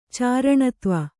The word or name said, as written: ಚಾರಣತ್ವ